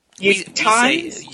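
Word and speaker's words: Utahns: How do you say that? – Utahans. – Tongs like kitchen tongs? – No, Utahans like whiskey or weird. – Okay. I would spell it Yoottonns. Utahans. Like that.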